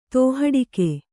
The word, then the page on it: ತೋಹಡಿಕೆ: Kannada